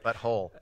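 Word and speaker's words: But Whole: Butthole.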